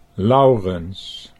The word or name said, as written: Laurens